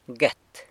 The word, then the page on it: guet: sanlucasquiavini